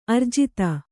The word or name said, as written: ಆರ್ಜಿತ